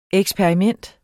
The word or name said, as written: eksperiment